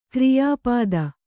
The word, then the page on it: ಕ್ರಿಯಾಪದ: kriyā pada